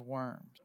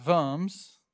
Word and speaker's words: Worms: And that's your Diet of Worms. Well, in German, the W is pronounced a V sound. So it's Worms. Worms.